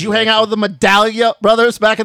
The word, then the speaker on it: Medaglia: And the Medaglia era and Tyler Medaglia. – Medaglia. – I call him Medaglia. – Medaglia. – Silent G. – Like a medallion steak.